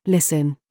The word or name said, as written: THIS